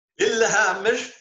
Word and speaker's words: Lillehammer: Lillehammer. – Jeez, you can even pronounce Swedish. Lillehammer. – That's better.